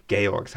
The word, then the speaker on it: Georg: Then she's trying to decide after the kids have performed at this this celebration in their house, in Georg's house.